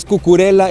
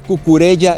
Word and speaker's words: Cucurella: Sí, no es Cucurella, es Cucurella, él mismo lo pronuncia de esta forma, y dice Cucurella que él prefiere jugar feo, que no le importa ser un fútbol horrible, pero seguir avanzando.